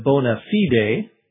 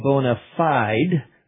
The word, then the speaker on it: bona fide: Bona fide, which in Latin would be something closer to bona fide, means in good faith. But this is America, so we pronounce it as if it were an English word. We say bona fide with the E silent and the I long on the second word.